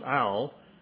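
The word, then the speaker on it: al (עַל): It's a little Hebrew word pronounced, the best we can do is pronounce it al, like an owl in a tree, a bird. It's A-L in Hebrew.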